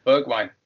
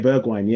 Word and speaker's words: Bergwijn: Bergwijn. – Yeah, Bergwijn, yeah.